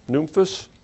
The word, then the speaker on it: Nymphas: So anyway, verse 15, Paul says, "Salute the brethren which are in Laodicea and Nymphas," is how it's pronounced. Nymphas, "and the church which is in his house."